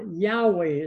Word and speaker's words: Yahweh: Yahweh, as it's often pronounced.